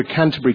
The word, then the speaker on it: Canterbury: It's called A Canterbury Kiss from my last record, and I just want to make it clear once and for all that that word Canterbury has three syllables in it.